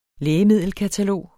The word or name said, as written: lægemiddelkatalog